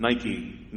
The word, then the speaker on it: Nike: you would pronounce it Nike, Nike, victory.